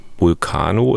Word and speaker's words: Vulcano: Man nennt sie Vulcano, ist das richtig ausgesprochen? Ist richtig ausgesprochen, ja.